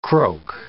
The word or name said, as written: croak